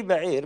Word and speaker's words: بعير: بئر لا هي هي بعير بس انه الياء مش مكتوبه يعني ايوه لان الياء من حروف العله ينطق ولا يكتب يقول لك بعر وهو البعير او الجمل